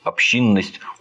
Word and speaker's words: общинность: Японцы, используя присущие японскому народу качества, то есть, например, общинность, общинность, я не знаю, как правильно ударение. – Общинность. – Общинность, наверное, да.